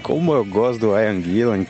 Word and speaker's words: Ian Gillan: engraçado porque se escreve I-A-N, por que, que a gente fala a Ian Gillan? – Você tá entendendo?